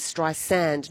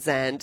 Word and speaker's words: Streisand: He says, "Hi, Barbara Streisand is very insistent about pronunciation. It is Streisand, not Zand.